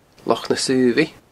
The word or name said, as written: Loch na Saobhaidh